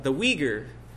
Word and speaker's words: Uyghur: In preparing for Amos, I came across an article in World Magazine on the Uyghur peoples that are Muslim in China. And they are spelled this way, U-Y-G-H-U-R. It's pronounced, if I can trust the internet, and that's always debatable, Uyghur.